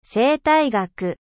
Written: ecologie

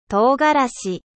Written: piment

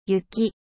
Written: neige

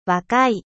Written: jeune

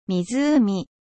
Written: lac

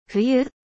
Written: hiver

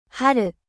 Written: 春

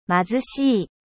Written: 貧しい

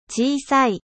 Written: 小さい

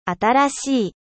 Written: nouveau

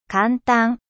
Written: facile

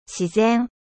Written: naturel

